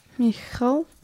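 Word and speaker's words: Michał: How do you pronounce his name? – Mykola Leshezik. – Michal. – It's M-I-C-H-A-L. – Look, Michael. – M-I-C-H. Yeah, but he's from Poland.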